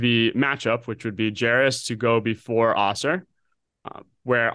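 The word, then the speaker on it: Jarace: And if he goes four, like Walker, and I'm going to draw, how do you pronounce Walker's first name? – Jairus. I thought it was Jairus.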